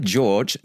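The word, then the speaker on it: Georg Solti: He is properly pronounced as Georg, right? – Yes. – Georg Scholti. – Yes. – But he prefers to be called George Scholti.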